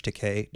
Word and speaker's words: Takei: As an aside, if you don't follow George Takei, thank you.